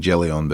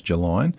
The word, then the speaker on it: Gelion: I was saying Gelion, but apparently it's Gelion.